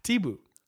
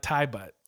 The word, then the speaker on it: Thibaut: Thibaut. Not thigh butt. Thigh butt. Like... Some fucking American over here can't pronounce this.